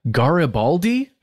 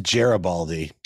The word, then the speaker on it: Garibaldi: The place is called Taqueria Garibaldi. I think that's how you would say it. – I think it's Garibaldi. – Garibaldi? – I think so. How's it spelled with an E-R or an A-R? – G-A-R-I-B-A-L-D-I. – Garibaldi? – Yeah, I'm probably like Americanizing it, but I think it might be either Gera or Garibaldi.